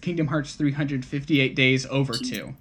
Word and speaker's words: Kingdom Hearts 358/2 Days: The way they want you to pronounce it is Kingdom Hearts 358 days over 2.